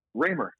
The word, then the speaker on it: Ramer: Raymer.